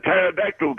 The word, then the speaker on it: pterodactyl: Why can't you hear a pterodactyl go to the bathroom? – Why? – Because the P is silent.